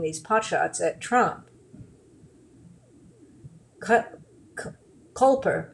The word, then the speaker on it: Koelper: How do you pronounce the O-E? – I'm pretty sure it's pronounced Culper. – Oh, well, so Joel would be Cole. I'm going to say Culper. K-O-E-L-P-E-R.